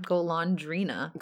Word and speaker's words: Golondrina: No, Golondrina. – You're forgetting the N. Golondrina.